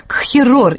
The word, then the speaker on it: кхерор: Этимологию этого слова надо искать в чеченском языке, на котором он произносится как «кхерор» и означает «устрашение».